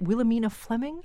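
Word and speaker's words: Williamina Fleming: Wilhelmina Fleming? – Yes.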